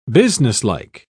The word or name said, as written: businesslike